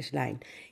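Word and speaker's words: Schlein: Λοιπόν, αυτό το κορίτσι στην Ιταλία το λένε Έλενα Έθελ Σλέιν, Σλάιν, και Σλάιν, ναι, προφέρεται Σλάιν.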